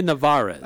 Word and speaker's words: Nevarez: So we'll hear from the new conference commissioner, Gloria Nevarez. Did I pronounce that right? – I think that's how it said. But I think, yes, Gloria Nevarez.